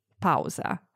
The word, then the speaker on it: pausa: To say break in Italian, we say pause, pausa. But attenzione, be careful, because the pronunciation is actually quite different. Let's listen again. – Pausa. – Notice anything interesting about the vowels? – Pausa.